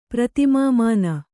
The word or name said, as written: ಪ್ರತಿಮಾಮಾನ